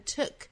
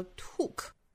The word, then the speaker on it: took: 首先我们来看 took 这个词。大家不要把它发成了 took, 应该是一个 took。